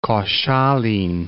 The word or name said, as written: Koszalin